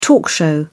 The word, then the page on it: talk show: British English